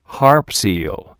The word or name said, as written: harp seal